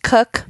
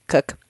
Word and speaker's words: Koch: My last name is pronounced Cook. Fun fact. This is like the least known fact about me on the internet, I would say. K-O-C-H, Cook.